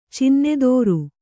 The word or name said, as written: ಚಿನ್ನಧಾರೆ